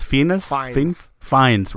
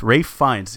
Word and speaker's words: Fiennes: Is it Ralph Fiennes? – Fiennes. – Ralph Fiennes. He has, it's not pronounced the way it's spelled, guys.